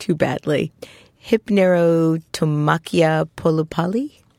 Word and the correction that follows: Hypnerotomachia Poliphili: polypoly. That's very close. That's very good. It's a mouthful. The Hypnerotomachia Poliphili.